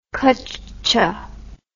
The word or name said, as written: kuccha